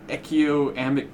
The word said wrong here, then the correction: anechoic: chamber. Did I pronounce that right? Anechoic chamber.